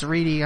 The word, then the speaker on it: Reddy: It's spelled Reddy, but it's Reedy.